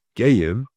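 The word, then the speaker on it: Geyim (גיים): It's spelled, Hashem said that you have two Goyim Bevetnech. It's spelled Gimel Yud Yud Mem, pronounced Geyim. The Kri is Goyim.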